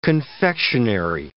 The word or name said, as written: confectionary